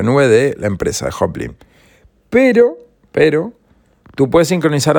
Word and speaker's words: Joplin: Se escribe J-O-P-L-I-N, Hoplin, ¿sí?